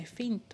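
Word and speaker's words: Fhin: F-H-I-N F-H-I-N というねフィンという方々で I don't know much about them because I just came across with their songs one day and I just love it so much that I've been listening to their songs ever since どこの方たちなんですかねフィンさ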